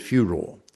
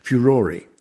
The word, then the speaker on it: furore: It comes from a Latin word, furor, F-U-R-O-R, meaning rage or anger. And what's happened is that the Americans leave the final E off, and so they normally pronounce it furor. But in our English, we keep the E on the end, and the dictionaries prefer the pronunciation furore. They also allow furor for people in Australia, so you can have it either way. But furore or furore, if you're going to be more Italian, because it's an Italian word as well, are the preferred ones.